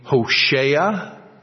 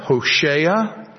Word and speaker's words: Hoshea: His name, Hosea, if you would pronounce it from the Hebrew, it would be Hoshea. Hosea, Hoshea. It is really the same name as Joshua and the same name as Yeshua, the Old Testament way of saying Jesus.